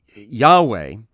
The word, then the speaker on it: Yahweh: Yahweh is the formal pronunciation, I think, in English, means he who is.